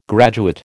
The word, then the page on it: graduate: American English